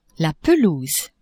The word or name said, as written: pelouse